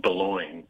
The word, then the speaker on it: Boulogne: Another example would be the Boulogne on the French coast is called Boulogne in England.